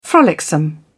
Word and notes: frolicsome: British English

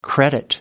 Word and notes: credit: American English

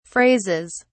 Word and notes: phrases: British English

